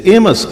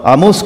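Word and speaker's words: Amos: آموس یہ بھی لکھنے والے کا نام ہے، اور عبرانی میں بھی اس کا نام آموس ہے، لیکن انگریزی میں اسے ایمس کہتے ہیں. زبانوں میں جب لفظ ڈھلتے ہیں تو ان کا تلفظ، ان کا لہجہ تھوڑا بدل جاتا ہے.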